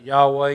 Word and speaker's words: Yahweh: And by the way, I pronounce that Yahweh. You can hear it pronounced all kind of different ways. And no one really knows because the Jews never pronounced that name, that special name of God.